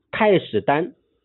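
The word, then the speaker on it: 太史儋: 史书上记载，周朝的太史太史丹，单人旁一个詹天佑的詹，太史丹，他的读音也通老丹的丹，他就建了秦献公。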